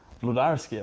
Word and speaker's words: Włodarski: Today I'm speaking with Robert Włodarski.